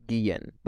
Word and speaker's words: Guillen: The Spanish way to say it, or the Hispanic way to say it is Guillen.